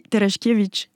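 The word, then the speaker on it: Tereszkiewicz: Parce que je vous ai demandé comment le prononcer, et comme moi je suis à moitié polonaise, vous m'aviez dit à la polonaise ça donne quoi, je vous ai dit Terechkevich. – Je préfère comme ça.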